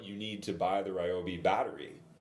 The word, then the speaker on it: Ryobi: But it was from the company Ryobi.